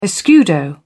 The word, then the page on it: escudo: British English